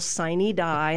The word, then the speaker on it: sine die: It's like a sine die or something like that.